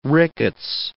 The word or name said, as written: rickets